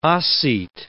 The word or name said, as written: Ossete